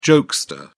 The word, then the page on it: jokester: British English